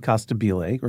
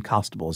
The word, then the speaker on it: Costabile: I remember the actor who was cast, David Costabile, or David Costable is how he pronounces his name. I always thought it was Costabile, but I ran it.